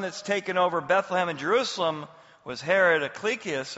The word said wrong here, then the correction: Archelaus: or however you say that. But whenever I pronounce something, it's wrong, just so you know. Archelaus is probably more accurate, was reigning over Judea.